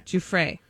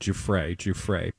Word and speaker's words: Giuffre: Which, by the way, I think we now know how to say her name. – Yeah, how do you say it? Giffrey. – Giuffre. Yeah, I watched a bunch of interviews over the weekend, and I'm assuming that's how she pronounces it, because every interview I listened to, they were like, Giuffre.